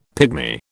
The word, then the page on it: pigmy: British English